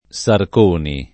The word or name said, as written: Sarconi